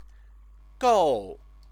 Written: gou

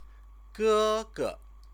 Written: gege

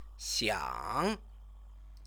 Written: xiang

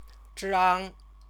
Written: zhang

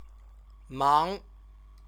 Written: mang

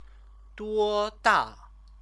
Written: duoda

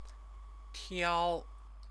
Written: tiao

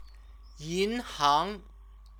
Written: yinhang

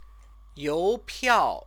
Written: youpiao

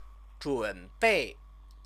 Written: zhunbei

